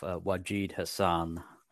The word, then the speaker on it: Wajid Hassan: So I'll introduce myself, Wajid Hassan.